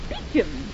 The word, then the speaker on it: Beauchamp: Why, B-E-A-U-C-H-A-M-P, Beecham.